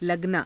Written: laganaa

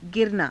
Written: giranaa

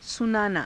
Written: sunaanaa

